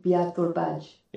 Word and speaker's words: Biatorbágy: No, Biatorbágy.